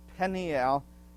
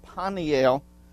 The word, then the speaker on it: Peniel: And that's why the term Peniel has a slight E sound to it instead of Paniel, which would have a more of an A sound because the vowel is being lengthened because there's a third syllable in there, kind of a third syllable that happens in some words that are pronounced in the southern areas of our country that have that extra syllable snuck in.